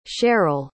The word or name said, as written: cherrell